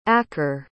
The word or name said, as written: acker